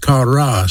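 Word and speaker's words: chara (χαρά): The Greek word for that word joy is pronounced chara, C-H-A-R-A.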